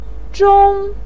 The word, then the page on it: zhong: 1